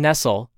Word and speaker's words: Nestle: Her last name is spelled like Nestle, as in the chocolate company, but it's not pronounced that way. So Marion Nestle, the book is called Food Politics.